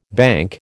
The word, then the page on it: banc: American English